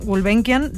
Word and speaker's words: Gulbenkian: la Fundación Gulbenkian de Lisboa.